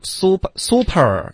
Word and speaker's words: super: No，苏 super，这个会这会带口音的，对方能听懂，可是会有口音，不是好习惯。